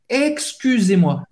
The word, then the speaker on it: excusez-moi: Ex, ex, tem son de x, x, x. Excusez-moi. Au U, et c'est U en française. c'est pronuncia U. U. Fais a boca do O et fala I. U. U. Excusez-moi. Excusez-moi. E Z, tem o som do E. Aberto. E. Excusez-moi. Outra frase essencial de sobrevivência.